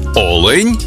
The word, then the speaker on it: олень: однині і в множині наголос падає на перший склад – олень та олені.